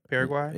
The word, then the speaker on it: Paraguay: Paraguay? – Yeah. – Okay, she was in Paraguay performing, or she was supposed to perform, and the show got canceled. – Yeah. – And so the fans in Paraguay started reaching out to her on Twitter saying, like, what happened?